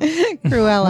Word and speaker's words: Cruella: All right, first up, Disney has delivered the first trailer for Cruella. Am I saying that right? – Probably not. – Cruella.